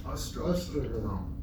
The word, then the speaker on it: Ostrava: Okay, there's a sefer called, I can't pronounce his name, the Ostrava. The Ostra, is that his name? – Ostrava. – Say it again. – Ostrava.